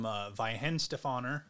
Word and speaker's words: Weihenstephaner: So you brought beer from Weihenstephaner.